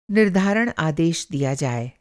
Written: आदेश

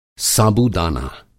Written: साबूदाना

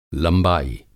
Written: लंबाई

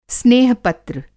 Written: स्नेह पत्र

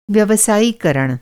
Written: व्यवसायीकरण

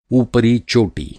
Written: ऊपरी

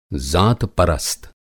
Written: ज़ातपरस्त

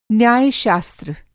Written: न्यायशास्त्र

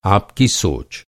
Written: सोच

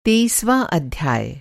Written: तेईसवाँ

